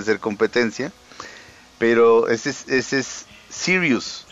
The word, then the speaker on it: Sirius: No, pero Sirius es como si se escribe como lo dices tú, Faust, pero es Sirius así como se escucha, y acá le ponen Cyrus.